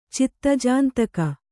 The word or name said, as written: ಚಿತ್ತಜಾಂತಕ